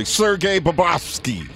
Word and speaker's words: Sergei Bobrovsky: My goalie, Sergei Bobrovsky.